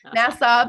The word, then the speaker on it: Nassau: Nassau, Bahamas.